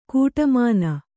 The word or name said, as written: ಕೋಟೆಮನೆ